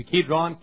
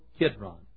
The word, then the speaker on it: Kidron: You'd say maybe Kidron, K-I-D-R-O-N. Some folks spell it K-E-D-R-O-N.